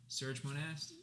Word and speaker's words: Serge Monast: This man goes by the name of Serge Monast, which I think that's how you say his name. Is it Serge Monast? – Yeah, I that's how I did.